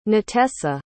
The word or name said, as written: natesa